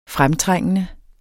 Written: fremtrængende

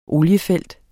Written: OLIEFELT

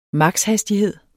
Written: maks.

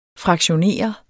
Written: fraktionere

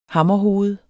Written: hammerhoved

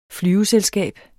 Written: flyveselskab

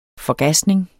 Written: forgasning

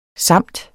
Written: samt

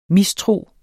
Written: mistro

